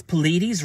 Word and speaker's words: Pleiades: What does Pleiades rhyme with? Hades.